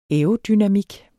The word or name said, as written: aerodynamik